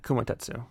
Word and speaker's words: Kumatetsu: I actually think the villain's characterization wasn't great, but the villain existing kind of characterized the opponent of Kumamatsu. – Yeah, Kumatetsu. – Yeah. – Like Kuma, like bear, and then Tetsu. – Yeah. – That's how you say it.